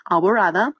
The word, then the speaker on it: Alborada: It's spelled A-L-B as in boy, O-R-A-D-A, Alborada.